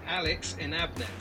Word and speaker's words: Alex Inabnet: Welcome to Investories, Alex Inabnet.